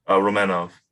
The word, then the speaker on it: Romanov: is it Romanov or Romanov? – Romanov. – Romanov. Okay, so I've been saying it wrong.